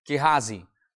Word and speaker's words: Gehazi: That's exactly how you pronounce it, by the way. Gehazi.